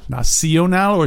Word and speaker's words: Nacional: How do you say it in Espanol? – La Nacional. – Nacional or Nacional? – Yeah, with a C. – Or Nacional. Like, not like nation. – That's right, yeah.